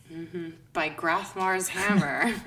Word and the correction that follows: Grabthar: Uh, Grabthar. – Grabthar.